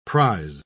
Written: prize

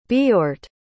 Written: Beorht

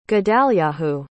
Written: gedalyahu

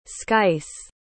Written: sky's

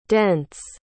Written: dents

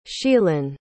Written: sheelin

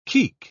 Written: keek

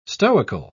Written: stoical

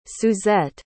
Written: suzette

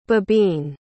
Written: bibine

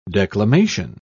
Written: declamation

